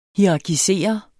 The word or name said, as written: hierarkisere